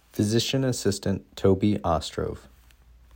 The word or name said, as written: Toby Ostrov